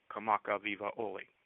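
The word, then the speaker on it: Kamaka Viva Oli (Kamakawiwoʻole): Same thing with Israel Kamaka Viva Oli. No stress on any of them. And the W in the middle of the word is pronounced as a V.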